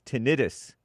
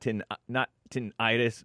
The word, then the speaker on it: tinnitus: It's tinnitus, not tinnitus, moron.